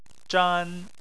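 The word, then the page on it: zhan: tone 1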